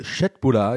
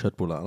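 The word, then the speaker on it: Köttbullar: Ja, also ich finde es auch bei Kött oder Chetbula, ich weiß nicht, ich glaube, so spricht man es richtig aus.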